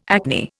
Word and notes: acne: Indian

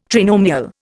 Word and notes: trinomial: Indian

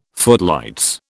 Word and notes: footlights: British English

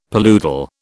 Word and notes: paludal: British English

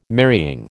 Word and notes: marrying: American English

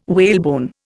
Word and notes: whalebone: Indian

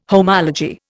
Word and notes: homology: Indian

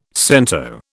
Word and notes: cento: British English

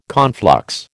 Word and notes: conflux: American English

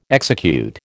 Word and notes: execute: American English

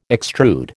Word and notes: extrude: American English

